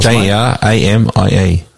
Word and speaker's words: Jeramie: J E R A M I E.